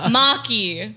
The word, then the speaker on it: Maki: It's all wrong. You said it all wrong. – Is it Ally Maka? – Ally Maka. No, it's Maki.